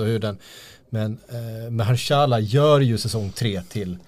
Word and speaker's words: Mahershala: Ma Mahershala.